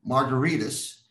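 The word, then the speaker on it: Margaritis: For example, my last name, Margaritas, is pronounced like a cocktail, but it's actually spelled like an inflammation because it ends in an I-T-I-S, like hepatitis, gingivitis, and laryngitis.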